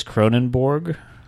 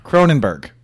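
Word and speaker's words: Kronenbourg: Where is Cronenborg? – Cronenberg.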